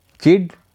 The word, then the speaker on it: chide: चिड, इसको चाइड भी बोलते हैं CHIDE, चिड।